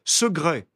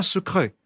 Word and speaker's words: secret: Le C se prononce quelquefois comme un G. Ainsi, on prononce segret et non pas secret. Second et non pas second. Glaude et non pas Claude. Quoique dans l'écriture, on doit absolument retenir le C. Et d'un seul coup, on découvre qu'au XVIIe siècle, on ne disait pas Claude, mais Glaude, et qu'on ne disait pas secret, mais segret.